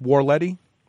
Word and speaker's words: Horletti: And then moving on, Kirkland Horletti. Did I pronounce it correctly this week? – The H is silent. It's Warletti.